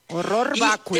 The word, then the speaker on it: horror vacui: Horror vacui. – Te voy a decir que horror vacui. El horror vacui, o sea, pero se pronuncia horror porque está en latín.